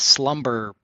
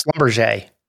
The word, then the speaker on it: Schlumberger: Well, this particular guy at JP Morgan pronounced ticker SLB as slumber. I can't even say it how phonetically you're supposed to say it. Schlumberger. – Schlumberger. – But that's not how you say it. – How do you say it? Oh, it's Schlumberger. – Schlumberger. Yes. And so. – Wow. Oh, French.